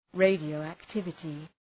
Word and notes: radioactivity: ,reıdıəʋæk’tıvətı

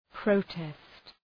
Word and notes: protest: prə’test

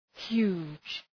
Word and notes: huge: hju:dʒ

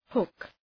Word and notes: hook: hʋk

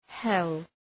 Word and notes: hell: hel

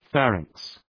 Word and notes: pharynx: ‘færıŋks

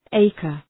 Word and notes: acre: ‘eıkər

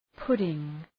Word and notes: pudding: {'pʋdıŋ}

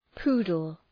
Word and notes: poodle: ‘pu:dəl

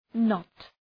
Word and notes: not: nəʋt

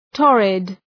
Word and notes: torrid: ‘tɔ:rıd

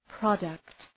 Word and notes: product: 'prɒdəkt

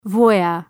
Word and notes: voyeur: vwɑ:’jɜ:r

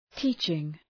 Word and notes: teaching: ‘ti:tʃıŋ

